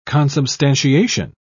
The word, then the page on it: consubstantiation: British English